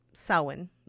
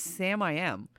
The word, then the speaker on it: Samhain: Samhain. But yeah, that's the original Celt pronunciation of the Harvest Festival.